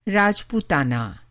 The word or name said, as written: राजपूताना